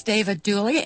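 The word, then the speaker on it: David Duley: Our guest today is David Dooley.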